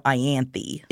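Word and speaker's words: Ianthe: No, in my brain, it's like the I, and then it's like an ant, so it's more Ianthi.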